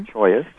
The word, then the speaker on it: cholla: choyas. – How do you spell choya? – C-H-O-L-L-A. – But it's pronounced choya.